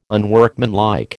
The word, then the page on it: unworkmanlike: American English